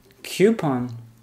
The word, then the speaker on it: coupon: Yo de toda la vida he dicho coupon. – Coupon. Mm-hmm. Coupon. No. The correct pronunciation is coupon.